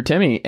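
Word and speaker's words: Timme: he's going to be right there with Drew Timmy, and or how do you pronounce it? I don't even remember now. Is it time? What is it? – Yeah, Timmy's right.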